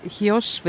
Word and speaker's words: Chios: Me oleme praegu Chiossi saarel, mille kohta kreeklased ise ütlevad Chios või tegelikult nad hääldavad seda pehmemalt, ma kuulesin. Chios pigem on see vist.